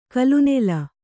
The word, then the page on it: ಕಲುನೆಲ: kalunela